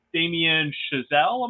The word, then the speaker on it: Damien Chazelle: Uh, so she has a long history with the Coen brothers and uh, director of Babylon, Damien Chazelle. Am I pronouncing that right? Mm-hmm. That is correct.